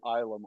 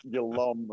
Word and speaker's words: Ilum: And thank you for pronouncing it Ilum. Otherwise, it would have been here calling it Yalum or something like that.